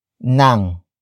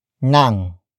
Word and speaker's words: ng: The next word is Nang. Nang.